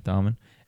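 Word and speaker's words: Dahmen: Joel Darman? How do you say that now? – Darman.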